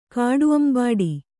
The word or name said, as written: ಕಾಡುಅಂಬಾಡಿ